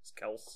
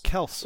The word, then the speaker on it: Kelce: But technically, his name is Kels. – Kels. – Which is why Andy Reid, the head coach, always calls him Kels. – Because he actually knows how to say his name.